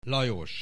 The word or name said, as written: Lajos